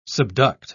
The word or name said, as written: subduct